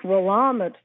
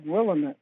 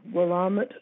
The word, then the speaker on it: Willamette: It's Willamette Valley. It looks like Willamette, but it's Willamette Valley. That's where the locals say it.